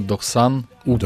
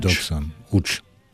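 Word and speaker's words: doqsan üç: 93 кримсько-татарською «Дохсан Уч». – «Дохсан Уч». – Так.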